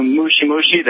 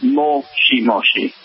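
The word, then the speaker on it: mochi mochi (もしもし): Like when you said you knew mushi mushi. That's actually incorrect pronunciation for hello over the phone. It's mochi mochi. – Mochi mochi. – Yes. Mushi means bug. You essentially said bug bug.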